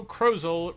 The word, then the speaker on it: Krozel: This week's Puzzle of the Quarter Month comes to us from Joe Krozel, rhymes with puzzle, and it was his Friday puzzle.